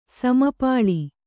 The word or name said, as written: ಸಮಪಾಳಿ